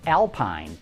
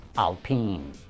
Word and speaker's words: Alpine: I know the name looks like it should be pronounced Alpine, but the proper pronunciation is Alpine.